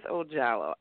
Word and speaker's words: Ojallo: I don't even know if I pronounced it right, Jallo, because if it was Spanish, it would be Hallo. But I just call her Earth. So, Earth, Mother Earth, how you doing, darling? – Hey, Terry, you're so cute. Absolutely, you pronounced it right. It is Earth Ojallo.